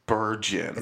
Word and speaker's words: Steenburgen: Is it Burgen or Bergen? Is it hard G? – I think it's Burgen. – It's a soft G?